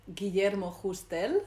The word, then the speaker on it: Guillermo Justel: You definitely should meet Guillermo Justel, that's okay. The Spanish pronunciation of his name.